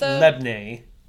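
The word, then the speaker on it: labneh: Lobne. Huh. Lobne. What are you? – Oh. It's like a flatbread? – Cream cheese yogurt-y thing. Huh. Lobne. So it's pronounced Lebne. Because it's some sort of Greek word.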